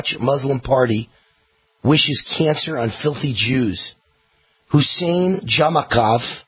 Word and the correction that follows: Jamakovic: excuse me, I didn't pronounce that right. Jamakovic, there it is.